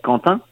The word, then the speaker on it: Quentin: So in French we say Quentin.